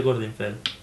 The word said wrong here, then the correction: Glorfindel: No, es Glorfindel.